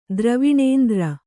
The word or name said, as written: ದ್ರವಿಣೇಂದ್ರ